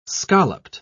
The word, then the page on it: scalloped: British English